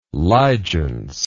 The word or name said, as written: ligeance